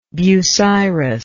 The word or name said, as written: bucyrus